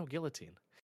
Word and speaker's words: guillotine: No, guillotine. It is the one French word that we do not pronounce properly. It is actually pronounced guillotine. I promise you, as someone who said guillotine for a while, learning that, I was like, "You've got to be shitting me!"